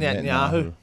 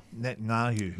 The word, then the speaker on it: Netanyahu: Benjamin Netanyahu. – Netanyahu, yeah.